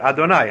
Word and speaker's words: Adonai: We're supposed to think that Hashem, as we pronounce it, Adonai, Hashem is the Adon HaKol.